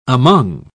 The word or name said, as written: among